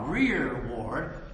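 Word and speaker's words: rereward: We would say this, rear, that's the modern way, R-E-R-E is nouns, pronounced R-E-A-R, rear ward, or the behind glory or the behind presence.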